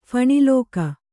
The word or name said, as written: ಫಣಿಲೋಕ